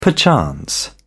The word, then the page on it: perchance: British English